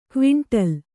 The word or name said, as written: ಕ್ವಿಂಟಾಲು